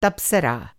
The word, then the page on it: तप्सरा: تبصرہ